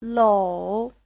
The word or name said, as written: lou3